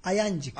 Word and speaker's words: Ayancık: Ee, yanlış te- telaffuz edersem lütfen düzeltin. Aydıncık. Ayancık. Ayancık. Evet.